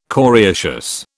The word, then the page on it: coriaceous: British English